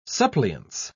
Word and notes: suppliance: British English

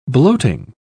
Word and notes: bloating: British English

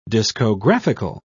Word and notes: discographical: British English

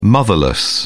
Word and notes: motherless: British English

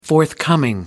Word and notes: forthcoming: American English

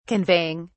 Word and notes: conveying: British English

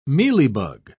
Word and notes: mealybug: British English

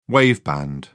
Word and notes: waveband: British English

